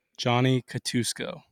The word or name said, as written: Johnny Catuosco